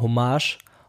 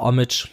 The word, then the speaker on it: Hommage: Kommt auch im April auf meinem neuen Album Hommage oder Homage, je nachdem, wie man es aussprechen möchte.